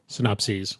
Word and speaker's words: synopses: How do you say multiple synopsis? – Synopses. – Synopses.